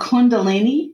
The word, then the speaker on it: Kundalini: Jennifer practices mindfulness, meditation, and Kundalini. Am I saying that right? – Yes.